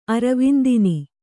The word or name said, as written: ಅರವಿಂದಿನಿ